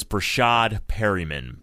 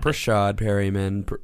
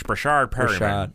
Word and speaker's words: Breshad Perriman: Let's put this pronunciation out there so everybody can get it. It is Breshad Perriman. – Not Breshad Perriman. Not Breshad or Breshad. – It's Breshad Perriman.